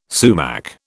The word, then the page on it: sumac: British English